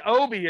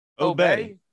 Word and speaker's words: Obey: I thought it was Obi. Are you getting all fucking fancy with the way you pronounce it? – How do you spell it? – Obey. O-B-E-Y.